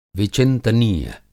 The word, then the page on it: विचिंतनीय: Hindi